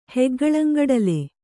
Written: ಹೆಗ್ಗಳ್ಳಂಗಡಲೆ

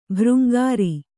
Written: ಭೃಂಗಾರಿ

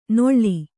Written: ನೊಳ್ಳಿ

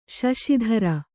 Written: ಶಶಿಧರ

